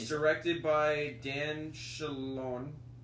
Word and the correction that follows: Scanlon: Dan Scanlon.